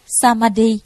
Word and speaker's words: Samadhi: Tam muội là tiếng Ấn Độ, nguyên âm đọc là Samadhi, nghĩa là chánh định, định tâm.